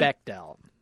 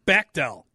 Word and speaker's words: Bechdel: Bechdel. – Bechdel.